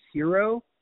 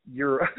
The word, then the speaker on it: gyro: You're pronouncing hero, and I swear it's euro.